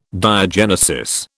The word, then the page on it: diagenesis: British English